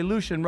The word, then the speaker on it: Lucien: Please welcome Lucien Greaves, everybody. Lucien, right? Did I say it right? – You did.